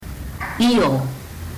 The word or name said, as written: io